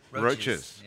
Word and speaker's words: Roches: And there's also, if you're a music fan, a Roches connection. Is that how you say it? Roches? – Roches. – Yeah. – You mean like cockroaches? – No, like the band.